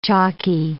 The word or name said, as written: chalky